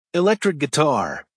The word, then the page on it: electric guitar: American English